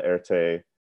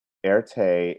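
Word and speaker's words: Erté: Erte is a French, nickname, I suppose, for the initials RT. – Oh, well, like so RT. So in French, R is pronounced air and T is pronounced te.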